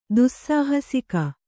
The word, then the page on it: ದುಸ್ಸಾಹಸಿಕ: Kannada